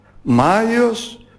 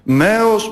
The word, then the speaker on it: Μάιος: Μάιος, Μαίος, Μάιος, βάλουν διαλυτικά η καλύτερη εκφορά και προφορά.